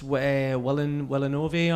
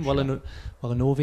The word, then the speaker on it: Villeneuve: And Denis Villeneuve, or is that how you say his name? Villeneuve, I think.